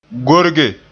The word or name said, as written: gorge